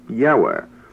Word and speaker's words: Yahweh: Enoch had written, Yahweh, that is I-E-U-E, should be pronounced Yahweh.